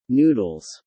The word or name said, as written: Noodles